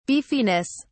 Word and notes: beefiness: British English

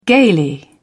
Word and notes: gaily: British English

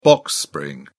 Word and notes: box spring: British English

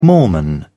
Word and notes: mormon: British English

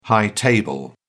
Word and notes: high table: British English

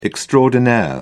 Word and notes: extraordinaire: British English

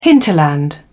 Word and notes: hinterland: British English

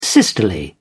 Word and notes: sisterly: British English

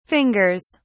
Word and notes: fingers: British English

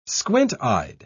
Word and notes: squint-eyed: British English

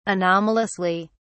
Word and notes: anomalously: British English

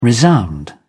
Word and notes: resound: British English

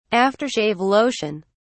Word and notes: after-shave lotion: British English

